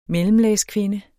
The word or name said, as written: mellemlagskvinde